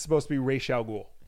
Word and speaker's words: Ra's al Ghul: Sulu Suju Mimi says, the only thing is, if pronounced Ra's al Ghul, like in the Nolan movies, that translates correctly in Arabic. If pronounced Raish, then no.